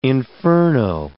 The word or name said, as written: inferno